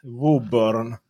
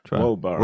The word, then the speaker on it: Woburn: Woburn. Wo typ Woburn. Som, wow! Don't burn me!